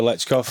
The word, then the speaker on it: Stoichkov: don't know if you remember Lechkov. – Of course. – Have I pronounced that right? Yeah.